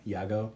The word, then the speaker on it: Yagoo: The CEO, his name is Yago. Y-A-G-O-O is how you spell it.